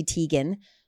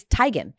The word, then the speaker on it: Teigen: It's not Chrissy Teigen, who's married to John Legend. It's Teigen. But same thing. She was like, everyone just said it wrong, and it just kind of became my name, so I just went with it.